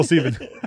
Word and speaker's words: Stephen: It's P H. It's S T E P H E N. – Hey, still Stephen. – It's pronounced Stephen.